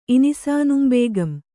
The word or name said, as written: ಇನಿಸಾನುಂಬೇಗಂ